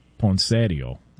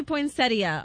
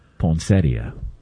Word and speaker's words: poinsettia: Well, the in-laws would call it poinsettio. – Oh, gosh. – What up, Ray? You know what I'm saying? Oh, can we get the proper pronunciation on this? – It does say poinsettia online.